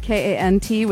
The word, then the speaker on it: Kant: It's called Can't Stop, except Can't is spelled K A N T with no apostrophe. So it's like Immanuel Kant.